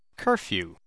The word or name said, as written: curfew